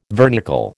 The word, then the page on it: vernicle: American English